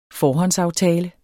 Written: forhåndsaftale

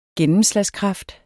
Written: gennemslagskraft

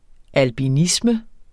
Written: albinisme

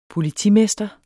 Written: politimester

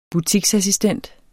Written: butiksassistent

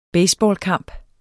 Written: baseballkamp

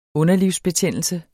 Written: underlivsbetændelse

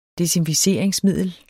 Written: desinficeringsmiddel

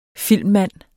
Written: filmmand

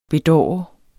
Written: bedårende

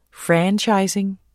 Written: franchising

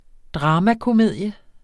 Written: dramakomedie

